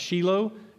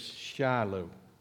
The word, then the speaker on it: Shiloh: In Israel, it's pronounced Shiloh, and so I may jump back and forth at times, but I'll try to stick with East Texas, Shiloh.